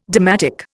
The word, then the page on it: dalmatic: Indian